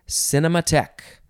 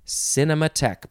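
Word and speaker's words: cinémathèque: Next is cinematheque. Cinematheque, but the end of the word is spelled T-H-E-Q-U-E.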